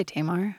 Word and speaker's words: Tamar: And and so you know, is it Tamar? – Tamar? Mm-hmm. How do you pronounce her name? I say Tamar. – Tamar. That's what I've always said.